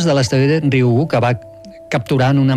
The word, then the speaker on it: Ryugu: Ryugu. – Ryugu. – És molt fàcil, com una I, Ryugu.